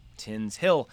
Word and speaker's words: Tinshill: Tinshill, pronounced Tinshill, is a district in Leeds, 4 miles north of Leeds city center, West Yorkshire, England.